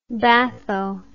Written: batho

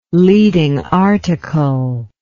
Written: leading article

